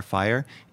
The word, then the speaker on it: Feier: So Fest in German is like a party, and a fire, it's spelled F-E-I-E-R, fire, is a celebration.